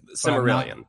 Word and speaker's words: Silmarillion: Is that how you say it? Silmarillion? – I think the second one is right. – Silmarillion.